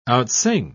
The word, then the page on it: outsing: British English